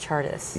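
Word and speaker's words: Csárdás: It's spelled like C-Z-A-R-Z. And I am... – And it's pronounced Chartus.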